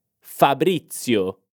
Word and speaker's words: Fabrizio: Attenzione alla pronuncia di questo nome italiano. Presta attenzione alla Z. È un suono come una T e una S insieme. Z, z. Ripeti con me. Fabrizio.